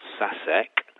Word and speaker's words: Šašek: And you would just see the name on the cover, Šašek, which of course over here we all would pronounce Sasek.